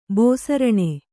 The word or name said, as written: ಬಸಿರಾನು